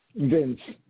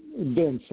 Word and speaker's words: Vince: This is Vince. I'm calling from New York. And can you say your name again? Vince. James? You know, the Vince, like V-I-N-C-E.